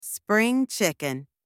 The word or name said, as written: spring chicken